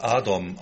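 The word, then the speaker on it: Adom (אדום): Edom, den Namen Edom finden wir auch heute noch in Israel in der hebräischen Sprache, aber er wird Adom ausgesprochen und das israelische Rote Kreuz nennt sich Magen David Adom, also der rote Schild Davids.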